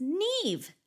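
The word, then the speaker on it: Niamh: Can I just say, it's spelled N-I-A-M like Mary H, and then she wrote phonetically that it's pronounced Neve.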